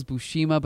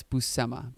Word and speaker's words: Buscema: No, I thought it was Buscema, then I thought it was Buscema, but no, it's Buscema.